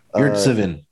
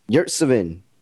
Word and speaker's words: Yurtseven: And how you pronounce my man's name again? – Yurtseven. – Yurtseven.